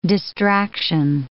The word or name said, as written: distraction